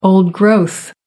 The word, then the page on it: old growth: American English